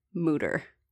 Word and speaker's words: Mütter: What I remember was he was born in America and went by Mütter and then traveled to Europe and learned that it's pronounced Mütter and changed it. The website on the Mütter Museum says to pronounce it Mütter. So I'll be pronouncing it Mütter.